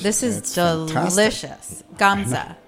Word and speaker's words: Gamza: Next, I want you to try pure Bulgarian indigenous varietal, which is Gamza. Gamza is also known as Kadarka. – Spell it. Gamza? – G-A-M-Z-A.